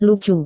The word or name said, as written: lucu